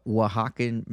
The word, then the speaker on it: Oaxaca: So uh, my next experience was a ceremonial one and with uh, Oaxacan Mexicans. And if y'all don't know what Oaxacan is, it's like O A X A C something, something, something, something. Who knows? Oaxaca. That's how you say it.